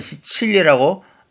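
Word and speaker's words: Sicilia: 이태인들은 시칠리라고 발음했습니다.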